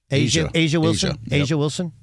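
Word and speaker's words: A'ja Wilson: And the other part that's really interesting is, how do you say, Aja Wilson? – Asia Wilson.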